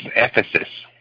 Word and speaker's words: Ephesus: It's Ephesus. – Oh, how do you spell that? – E-P-H-E-U-S. – Ephesus. E-P-H-E-S-U-S. – Ephesus. Ephesus, yes.